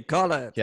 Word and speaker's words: Khaled: DJ Khaled.